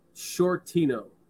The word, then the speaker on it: Sciortino: Les, my name is Dan Sciortino. So Mr. Shorty is spelled half of my last name, which is S C I O R T I N O.